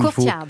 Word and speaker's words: Courtyard: En tout cas, je rappelle, vous êtes chef au Courtyard, j'arrive pas à le prononcer comme Courtyard. – Il faut. Courtyard. Merci beaucoup.